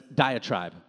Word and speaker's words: diatribe: It's actually a method taught by Socrates, and it's called deatrebo. I don't know if I'm saying that right, but it's diatribe is how you say it, diatribe.